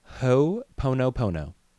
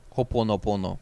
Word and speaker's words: Ho'oponopono: So it's called Ho'oponopono. And it's spelled H-O apostrophe O-P-O-N-O P-O-N-O. – Ho'oponopono, probably something like that.